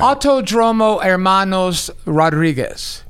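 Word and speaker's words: Autódromo Hermanos Rodríguez: Autodromo Hermanos Rodriguez, which is...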